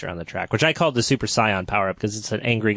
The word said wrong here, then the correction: Saiyan: And did you pronounce it Scion? – How is it fucking pronounced? I don't watch Dragon Ball. – That's certainly... That's like half and half what the English and Japanese then would be. In England, over in America, we call it a Saiyan.